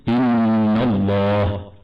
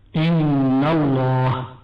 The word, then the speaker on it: الله: تفخم لام لفظ الجلالة كما قلنا بعد الفتح أو الضم، فمثالها بعد الفتح إن الله إن الله.